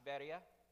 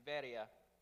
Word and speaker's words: Veria: It's spelled Berea, but it's pronounced Veria. And in Acts 17:10 through 15, we read of his work there in Veria.